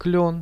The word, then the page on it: klion: Russian